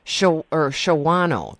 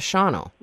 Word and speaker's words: Shawano: It's, and people put the A, they pronounce the A and they shouldn't. – Right. – Shawano. That's the Shawano instead of Shawano.